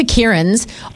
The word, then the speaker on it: Kieran: Kieran, also, we need to know, we've been looking for Kierans of all different spellings. How do you spell your name? – K-I-E-R-A-N.